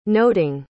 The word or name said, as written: noting